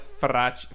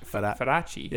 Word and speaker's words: Feraci: Obviously, they had an accent, but I always wondered how to pronounce my last name in Italy, and apparently, it's Feraci. Got to roll that R.